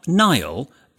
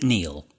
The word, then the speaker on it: Niall: Final letter is from, well, Niall, but sometimes people pronounce it Neil.